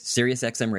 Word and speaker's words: Sirius XM: Am I saying it right? Is it Sirius XM? – Yes, it is. Sirius XM Radio.